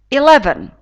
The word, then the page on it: eleven: i’levn